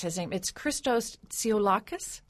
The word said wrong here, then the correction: Tsiolkas: Okay. – Tsiolkas.